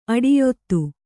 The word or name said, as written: ಅಡಿಯೊತ್ತು